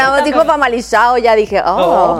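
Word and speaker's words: Famalicão: El famalizado. Sí. Porque no se dice famalicado.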